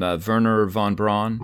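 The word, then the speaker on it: Wernher von Braun: And Werner, how do you say his name? Werner von Braun? – Correct, yes.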